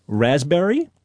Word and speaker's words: raspberry: Raspberry is? – The silent S. – P. – Well, P. Sorry. – Silent P, right.